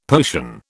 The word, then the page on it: potion: British English